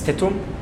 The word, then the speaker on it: Tetum: Sorry, it's Tetum.